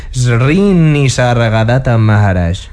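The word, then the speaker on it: Sri Nisargadatta Maharaj: Un par de ellas las ha sacado de algunos escritores, de Anthony Powell y de Srinisargadatta Maharaj.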